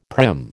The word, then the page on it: prim: American English